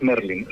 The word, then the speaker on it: Merlin: Es Merlin.